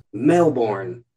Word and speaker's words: Melbourne: Melbourne, Australia.